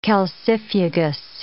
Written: calcifugous

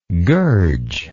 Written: gurge

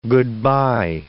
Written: goodbye

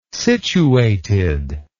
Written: situated

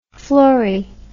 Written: fluori

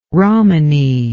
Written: romani

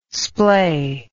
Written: splay

